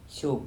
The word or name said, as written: Sop